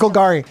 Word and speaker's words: Golgari: and Grav Gultari. That's not, I didn't say that right. Gultai. I'll have to ask. – Golgari.